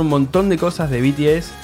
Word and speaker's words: BTS: Con el tema de popularidad, nos va a hablar un poquito de BTS, que es una... ¿Lo dije bien? De vuelta, sí, BTS.